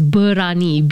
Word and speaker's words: Berani: Well, we spell it today as Berani, B-E-R-A-N-I. But I think this is based on the old spelling, right? Berani. So Berani means brave in Malay, by the way.